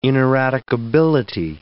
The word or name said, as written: ineradicability